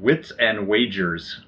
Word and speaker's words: Wits and Wagers: It's called Wits and Wagers. – Ooh. – Or as my mom mispronounced it when she first saw it, Wits and Waggers. So, of course, it became Wits and Waggers for the rest of the reunion. – Is it really dogs? – Yeah. Mom did this cute little wagging of her butt after she realized that she had said it wrong.